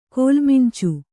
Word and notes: ಕೋಲುಮಂಚ: kōlu manca

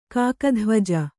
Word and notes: ಕಾಕಧ್ವಜ: Kannada

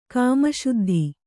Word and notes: ಕಾಮಶುದ್ಧಿ: Kannada

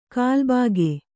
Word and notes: ಕಲ್ಬಾಗೆ: kannada

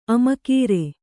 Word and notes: ಆಮಗರಿ: kannada

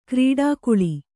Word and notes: ಕ್ರೀಡಾಕುಳಿ: krīḍakuḷi; kannada

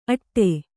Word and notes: ಅಟ್ಟೆ: aṭṭe

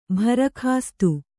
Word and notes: ಭರಕಾಸ್ತು: bharakāstu

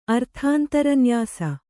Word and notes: ಅರ್ಥಾಂತರನ್ಯಾಸ: arthāntaranyāsa

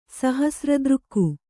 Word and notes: ಸಹಸ್ರದೃಕ್ಕು: Kannada

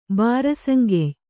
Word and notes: ಬಾರಸಂಗೆಯ್: Kannada

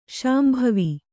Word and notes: ಶಾಂಭವಿ: śambhavi

